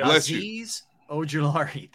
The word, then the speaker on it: Azeez Ojulari: So Giants lost an outside linebacker, Azeez Ojulari. – Yeah, Azeez Ojulari.